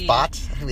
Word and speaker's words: Bhatt: I think it's Bhatt. The H is silent.